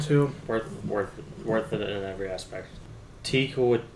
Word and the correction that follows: Taika Waititi: I think it's pronounced Taika Waititi.